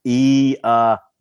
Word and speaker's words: Iamaleava: Iamaleava, you know, and the ma and lay, they're in capital letters, so you emphasize that syllable, Dustin.